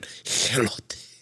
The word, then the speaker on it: Elote: No, I've been actually recently making elote. – Okay. – Oh, let me make sure I say that right. Elote. – It's just an E. – Yeah, yeah. There's no H.